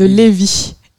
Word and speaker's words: Lévis: Faut juste pas prononcer le, S. – de Lévis.